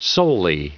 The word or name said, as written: solely